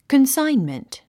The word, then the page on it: consignment: kənsáinmənt; コンサインメント